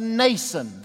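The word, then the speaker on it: Mnason: taking us to Nason, the M is silent, N-A-S-O-N, taking us to Nason of Cyprus, a disciple of long standing with whom we were to lodge.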